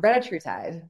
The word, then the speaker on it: retatrutide: and like retatrutide, I always pronounce that right wrong. – Retatrutide. – Yeah, retatrutide.